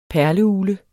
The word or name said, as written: perleugle